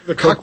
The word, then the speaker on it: Koch: How does our Koch brothers... – The Koch brothers. – I mispronounced it again. Koch brothers.